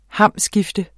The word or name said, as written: hamskifte